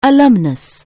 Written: alumnus

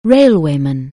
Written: railwayman

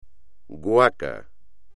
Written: guaca

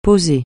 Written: poser

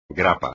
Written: grapa

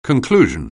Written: conclusion

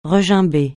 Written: regimber

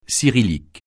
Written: cyrillique

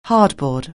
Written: hardboard

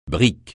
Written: brique

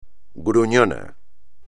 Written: gruñón

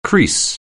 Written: crease

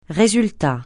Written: résultat

